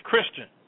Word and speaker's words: Christine: It's spelled C-H-R-I-S-T-I-N-E. Looks like Christine, but it's Christian, okay?